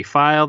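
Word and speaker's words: Feil: File. That's F-E-I-L, also on Letterboxd under the same name.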